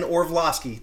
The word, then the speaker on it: Orlovsky: Dan Orlovsky, did I say his name right? – Mm-hmm.